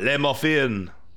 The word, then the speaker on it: Le Muffin: And it's like, the Le is French, but Muffin is English or American. – Oh, wow. Okay. – But we're going to say Le Muffin.